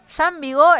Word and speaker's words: Jean Vigo: Jean Vigo, espero estar eh, pronunciándolo bien.